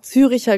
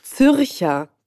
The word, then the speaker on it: Zürcher: Hier steht nämlich auch Züricher Geschnetzeltes. Es heißt Zürcher. – Zürcher. Ah ja, muss ich das I wegnehmen.